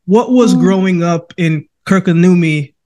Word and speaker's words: Kirkkonummi: Kirkkonummi, Finland. Did I say that right? – Kirkkonummi, yeah. – I was close.